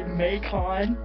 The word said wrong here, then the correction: Macon: Because that's not how you say it, but it's okay, I know what you mean. – Oh, how do you say it then? – You're putting emphasis on the second syllable. It's fine, it's fine. I just live in Georgia. – Macon. Okay. Hey. – It's like bacon, but with an M.